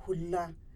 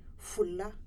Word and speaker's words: Fula: You say Hula. But in Nigeria, they will say Fula. You know it is the same, but the pronunciation is a little bit different.